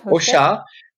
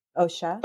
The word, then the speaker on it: Hauchat: Ocha. – Ocha. – Ocha. H-A-U-C-H-A-T.